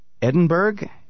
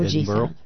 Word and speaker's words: Edinburgh: Eleanor Tiernan has appeared at both the Edinburgh, is that how you say it? – No, Edinburgh.